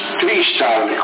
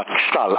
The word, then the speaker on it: XTAL: Ma anche io l'ho già sentito nominare, Tristan, come con la TIE, Tristan. – No, XTAL, si pronunciava XTAL, allora ti faccio lo spelling, X-Ray, Tango, Alfa, Lima, X-Ray, trattino, Tango, Alfa, Lima, c'era un trattino, sì.